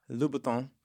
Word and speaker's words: Louboutin: Louboutin.